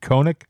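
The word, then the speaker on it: Konnech: Koenig, that's K-O-N-N-E-C-H. I think you pronounce it Koenig.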